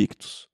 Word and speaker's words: Ichthus: Só lembrando que Ictus se escreve I-C-H-T-H-U-S.